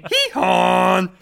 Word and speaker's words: Gijón: Sporting Gijón.